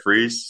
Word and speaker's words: Fries: My name's Derek Freeze, spelled like fries.